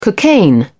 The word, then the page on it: cocaine: British English